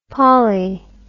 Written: pollie

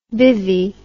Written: bivvy

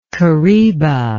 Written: kariba

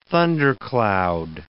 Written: thundercloud

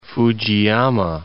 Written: Fujiyama

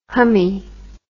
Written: hummy

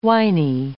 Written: winey

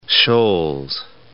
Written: Scholes

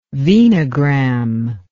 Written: venogram

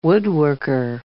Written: woodworker

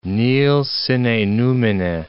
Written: nil sine numine

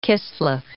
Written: Kislev

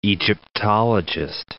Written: Egyptologist

